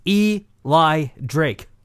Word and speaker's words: Eli Drake: His name is Eli Drake. – That is how you pronounce it.